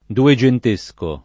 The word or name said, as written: dugentesco